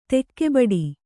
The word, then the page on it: ತೂಗುಬಿಡು: tūgu biḍu